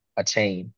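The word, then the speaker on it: Achane: A-chain. – Oh, hey, whatever you want to call it. – 5-6-4-3, yeah, right. – Bro, the man came out and said my last name is pronounced A-chain.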